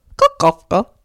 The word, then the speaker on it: Kukovka: That's Notes on an Execution by Danya Kukovka. – Kukovka. – Uh-huh. That's how you say it.